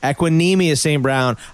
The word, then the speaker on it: Equanimeous St. Brown: I don't think I'm getting his name right. I'm probably butchering it. I've heard say it again. I'll tell you. – Equanimeous. All right. Equanimeous St. Brown.